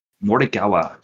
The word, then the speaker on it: Morikawa: Morikawa, getting it done.